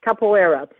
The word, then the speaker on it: capoeira: Capoeira.